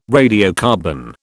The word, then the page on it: radiocarbon: British English